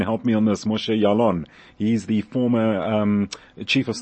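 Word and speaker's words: Ya'alon: Well, first of all, you pronounced Yalon perfectly.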